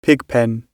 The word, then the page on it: pigpen: British English